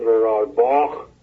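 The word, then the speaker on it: Rohrabacher: اما این مسئله آقای نماینده کنگره آمریکا آقای دان رورالباخ، ایشون یا باک که اسمش آلمانیه ولی به هر حال تلفظ انگلیسیش باک میشه.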